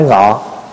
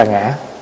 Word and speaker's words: ngã: Cho nên người Hoa đó viết chữ là viết ngã, mà nói thì nói ngọ. Ngọ với nị á, nói thì nói ngọ, nhưng mà khi viết ra chữ là ngã, đọc là ngã.